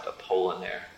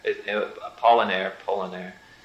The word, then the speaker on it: Apollinaire: Should maybe pronounced Apollinaire. Apollinaire.